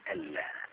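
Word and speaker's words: ألّا: هي لما بتتكتب بتتكتب أن لا، أشهد أن لا إله إلا الله، بتتكتب أن لا، لكن النون ما تنطقش، لذلك أنا حاطط لك عليها سكون وحاطط شد على اللام، يعني النون لا تنطق.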